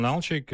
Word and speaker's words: Nalchik: It was called Nalchik. Is that how you say it? – Yeah, it was called Nalchik.